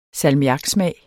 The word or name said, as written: salmiaksmag